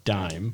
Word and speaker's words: Daim: How do you pronounce that? – Daim. – Dime.